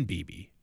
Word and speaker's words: Beebe: Cooper Beebe. – And I said, I think it, you asked me how to pronounce it, and I said, I think it's the same as Don Beebe.